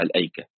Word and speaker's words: الأيكة: فمثلا في سورة الحجر مكتوبة الأيكة كما هو معروف، لكن في سورة الشعراء وفي سورة صاد كتبت اللام وبعدين همزة على نبرة، يعني همزة بين اللام والياء، اللي هي اللام وبعدين همزة وبعدين ياء وبعدين كاف وبعدين تاء، لكن هي تنطق هكذا الأيكة.